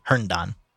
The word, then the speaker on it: Herndon: Difficult in the untidy office Lincoln shared with his younger partner, William Hendon. Herndon. Am I saying that right? – Yes. – William Herndon? – Herndon.